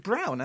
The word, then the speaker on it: Braun: Apparently you pronounce it brown, as in the colour.